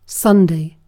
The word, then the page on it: sunnuntai: [ˈsunːun̪t̪ɑi]; /ˈsunː.un.tɑi/